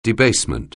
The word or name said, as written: debasement